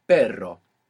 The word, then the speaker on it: perro: So initial R, or two R's together inside the word, is going to pronounce this double R. So the word for but in Spanish is pero. – Pero. – P-E-R-O. And the word for dog is perro. P-E-R-R-O.